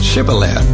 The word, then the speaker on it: Shibboleth: Anyone who tried to cross had to say the word Shibboleth. Those from Ephraim could not pronounce it correctly, saying a th sound rather than a sh sound, and thereby were identified.